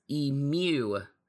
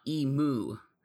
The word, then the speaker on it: emu: The bird's name is pronounced emu, not emu.